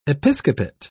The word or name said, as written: episcopate